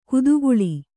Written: ಕುದುಗುಳಿ

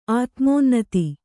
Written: ಆತ್ಮೋನ್ನತಿ

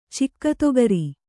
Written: ಚಿಕ್ಕತೊಗರಿ

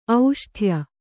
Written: ಔಷ್ಠ್ಯ

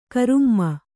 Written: ಗರಮ್

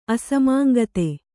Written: ಅಸಮಾಂಗತೆ

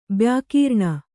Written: ಬ್ಯಾಕೀರ್ಣ

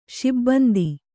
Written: ಶಿಬಂದಿ

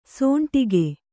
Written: ಸೋಂಟಿಗೆ

